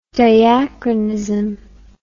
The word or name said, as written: diachronism